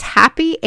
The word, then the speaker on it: Hapi: The Egyptians worshipped the Nile because it brought them life, and they even had a god named, I think it's Happy, H-A-P-I.